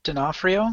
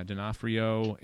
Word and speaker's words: D'Onofrio: D'Onofrio. – Yeah, D'Onofrio, and your Gary Sinise and Madeline Stowe.